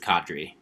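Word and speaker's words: Qadree: They did pick up a guy named, I think you say his name, Kadri Olison. – I'm pretty sure it's Kadri, yeah.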